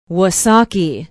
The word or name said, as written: wausaukee